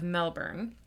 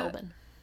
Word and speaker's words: Melbourne: It's northeast of Melbourne, and like much of Australia... – Melbourne.